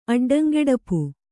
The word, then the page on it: ಅಡ್ಡಂಗೆಡಪು: Kannada